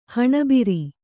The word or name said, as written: ಹೆಣಭಾರ